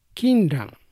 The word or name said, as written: Kinran